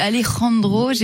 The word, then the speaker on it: Alejandro: Oui, mais moi aussi, Alejandro, González, Iñárritu.